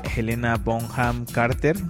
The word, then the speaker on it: Helena Bonham Carter: Y pues a Helena Bonham Carter, no sé si lo pronuncié bien, Trapsam.